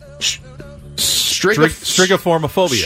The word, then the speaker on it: Strigiformophobia: Strigiformophobia.